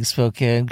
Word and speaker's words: Spokane: You caught me pronouncing Spokane correctly.